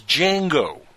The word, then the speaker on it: Django: The D is silent. Is Django? – Whatever.